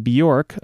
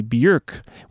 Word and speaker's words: Björk: Though many English speakers pronounce her name Björk, a more accurate approximation would be Björk, which she has pointed out rhymes with jerk.